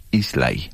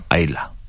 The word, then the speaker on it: Islay: Se llama Islay o uh-huh. Ayla, uh-huh. según lo queramos pronunciar. Las dos formas es correcto.